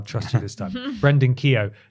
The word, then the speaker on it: Keogh: It's a tweet from video game industry and culture researcher Brendan, how would you pronounce that? K-E-O-G-H? – K-E-O. – Keo. – Keo. There was actually someone at my school whose surname was Keo.